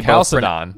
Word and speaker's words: Chalcedon: I was asking before the show how he pronounced that word, because I've heard it three different ways. I've heard it Chalcedon. – We both pronounce it Chalcedon. – Yeah, which that means that that's the correct one.